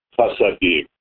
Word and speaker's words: Hasidim: The word is pronounced Hasadim.